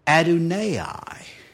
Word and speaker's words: Adonai: And the word Lord here is the word, it's pronounced Adonai.